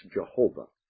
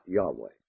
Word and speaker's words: YHWH: YHWH should be pronounced Jehovah, not Yahweh.